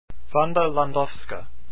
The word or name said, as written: landowska